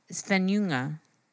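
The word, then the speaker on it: Svenljunga: I said Junga and it's like, no, it's just like Svenjunga. – There's no, like, you don't really pronounce the J.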